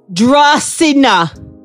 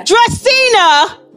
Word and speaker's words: Dracena: Dracena. – Yes. – Did I say it wrong? – Dracena.